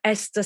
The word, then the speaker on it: Esther: Esther Stanhope. And my name is spelled E-S-T-H-E-R, Esther Stanhope.